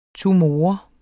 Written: tumor

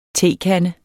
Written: tekande

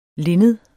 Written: LINNEDETS

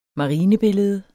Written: marinebillede

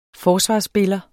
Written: forsvarsspiller

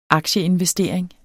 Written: aktieinvestering

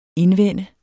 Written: indvende

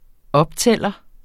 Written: optæller

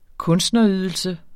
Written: kunstnerydelse

